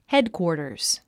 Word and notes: headquarters: hédkwɔ̀ːrtərz; ヘドクウォータァズ

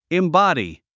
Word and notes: embody: imbάdi; インボディ

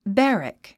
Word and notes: barrack: bǽrək; バァラァック

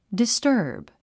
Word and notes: disturb: distə́ːrb; ディスターブ

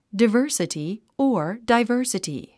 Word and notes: diversity: dəvə́ːrsəti; デヴァースィティ

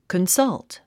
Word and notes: consult: kənsʌ’lt; コンサルト

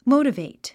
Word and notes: motivate: móutəvèit; モゥタベェイト

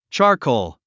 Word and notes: charcoal: tʃάː r kòul; チャァコォ

